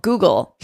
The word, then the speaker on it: guggul: It's called Google, not like the company. It's spelled G-U-G-G-U-L.